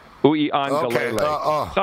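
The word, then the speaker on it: Uiagalelei: It's Uiangalele. Uiangalele. – Okay. – It's not too bad. It's six syllables, but it kind of flows.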